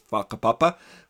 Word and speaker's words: whakapapa: spelled with a WH, but pronounced whakapapa with an F.